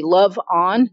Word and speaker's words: LaVonne: Because it's not LoveOn like the name, even though that is your birth name, I think. You spell it differently, like actual love. – LoveOn.